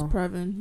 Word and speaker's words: Previn: I really hope I'm saying his name right. Previn, I don't know. – It's Previn, yeah.